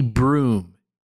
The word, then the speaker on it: Brougham: It's spelled Brougham. I don't think anyone I knew ever pronounced it. – Wow. – But we maybe would have said Broham. But apparently broom is actually the standard pronunciation.